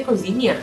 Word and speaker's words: cozinha: Haknya dibaca Kozinia. Kozinia.